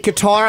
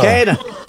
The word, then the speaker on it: Kattar: The only time I say Qatar, like I said, is when I look at your last name alone. For some reason, it just goes to that. But when I see your whole name written out, I get it correct.